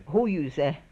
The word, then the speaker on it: Hoyuze: Ho, how would you say it? The fish hook. Is it Hoyuze or Hoyuze? – Hoyuze. – Hoyuze. – Hoyuze. – Alright.